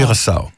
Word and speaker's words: Curaçao: Det Curaçao, tror jeg, det udtales. Curaçao. – Curaçao.